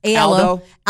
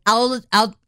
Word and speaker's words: Aldo: How do I pronounce? – Aldo. – Aldo. Is it? – I think it's Aldo. – Does it have a D in it? – I think so. Yeah.